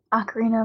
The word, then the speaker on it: Ocarina: Or Ocarina. I can't pronounce that word. Of Time. – Ocarina of Time.